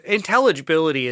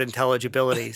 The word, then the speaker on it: intelligibility: Intelligibility is, I didn't say that right. I smeared the word intelligibility.